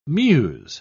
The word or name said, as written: mews